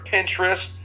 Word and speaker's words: Pinterest: I've heard of Pinterest.